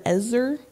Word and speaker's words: ezer: But the word helper in Hebrew is actually the word ezer. And did I say that right? – You did.